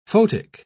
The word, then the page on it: photic: British English